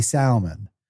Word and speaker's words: salmon: Can I tell you that this girl I used to date in college, it's very smart woman, used to say salmon. – Ugh. – And I was like, hey, there is no, the L is silent.